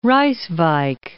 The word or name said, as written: Rijswijk